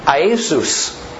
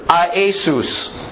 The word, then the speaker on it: Iesus: Iesus. That is, in Greek, it's a three-syllable word. Iesus.